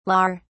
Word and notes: lawer: British English

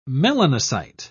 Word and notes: melanocyte: British English

